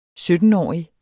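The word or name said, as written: Syttenårige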